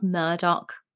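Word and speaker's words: Murdaugh: I say murder, but apparently it's pronounced Murdoch.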